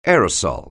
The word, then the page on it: aerosol: anglais